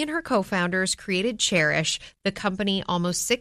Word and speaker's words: Chairish: And this week's No Limits Entrepreneur is Anna Brockway, who is the co founder and president of Chairish. And just so you know, it is spelled like a chair, C H A I R ish because it is a curated online marketplace for buying and selling vintage and used furniture.